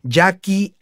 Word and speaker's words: Yaqui: Nada más acuérdense que Jackie se escribe como los indios de Sonora, es decir, Y-A-Q de queso, U y latina. ¿eh? Jackie Andrés Martínez, ese es mi nombre, y .com es mi página web.